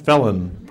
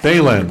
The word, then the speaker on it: Phelan: Second, Bluffton by Matt Fallon, pronounced Phelan. You can't write this, folks. I was just reading, yes. It's just, they should have put it beforehand. Or just at least spelled it phonetically.